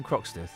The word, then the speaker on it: Croxteth: There was a young lady from Croxteth. Is that where... – It rhymes with Toxteth.